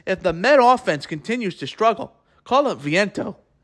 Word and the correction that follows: Vientos: Vientos. Sorry if I butchered his name. Vientos, that's the right pronunciation.